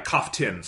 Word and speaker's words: Kaftin: it's probably in episode two, you have the sequence where the Cybermat like awakens up with Victoria and Kafkin, and winds up attacking, am I getting her name right? Yeah, Kaftin, sorry. Yeah. Winds up attacking Kaftin, and then Victoria kind of has the run of the situation, is able to get the hatch open and everything.